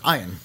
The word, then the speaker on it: Ian: Oh, and lest we forget, the great Ian Ziering. – No, it's Ian. – Is it Ian? – It's pronounced Ian.